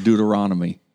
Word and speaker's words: Deuteronomy: Did you say Deuteronomy? – No, I said Deuteronomy. I said it right.